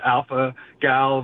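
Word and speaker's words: alpha-gal: My first time I called in was to correct you when you were talking about alpha gal versus alpha gale. – Oh, yes, that's right. I said it wrong.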